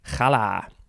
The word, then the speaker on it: challah: This is a Yiddish word. They spell it K-H-A-L-E from the Hebrew H-A-L-L-A-H. Challah.